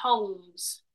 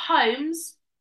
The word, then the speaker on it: Holmes: But it's a silent Yeah, it's a silent L. – So it's not Holmes. It's Holmes.